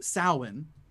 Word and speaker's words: Samhain: And it's pronounced Samhain, but it's spelled like Samhain, which is just, you know, Gaelic's a difficult language as far as I can tell. So it's pronounced Samhain.